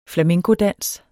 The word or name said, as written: flamencodans